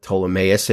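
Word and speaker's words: Ptolemais: By the way, one more Bible trivia question you could stump the Bible nerds with is, what chapter in the Bible has both a silent N and a silent P? Which this one does with Ptolemaeus Mnason.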